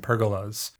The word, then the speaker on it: pergolas: P-E-R-G-O-L-A-S. It's like Legolas, only absolutely not.